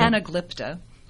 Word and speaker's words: Anaglypta: If you do have plaster walls, which many of you do, the anaglyptical paper. Did I say that right? That's anaglypta. – Oh, okay. Leave it to a librarian. Okay. So thank you. I stand corrected.